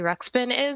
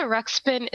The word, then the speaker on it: Ruxpin: I realized people born after 93 don't know what a Teddy Ruxpin is. So Ruxpin is spelled R-U-X-P-I-N.